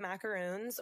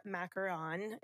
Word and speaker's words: macaron: The macaroons or macaron is what I've learned is how you're supposed to pronounce those cookies.